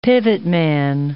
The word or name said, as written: pivotman